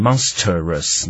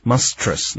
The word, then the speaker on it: monstrous: 那 monstrous 这个字啊，是像怪物一样的，它的名词也很常见，叫 monster, 好，是 M-O-N-S-T-E-R M-O-N-S-T-E-R monster, 但是大家记得发音有点变化，你不要变那个念形容词的时候不要念成 monstrous, 那就很奇怪了。好，你要念 monstrous, 那 trus 是一个音节。